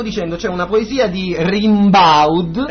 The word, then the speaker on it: Rimbaud: Allora si pronuncia Arthur Rimbaud.